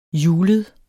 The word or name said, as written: julet